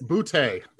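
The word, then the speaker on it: Boutet: It could have been, was Chris Bouet, how do you pronounce it? – Bouté, it's French. – It's Chris Bouté.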